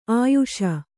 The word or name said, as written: ಆಯುಷಿ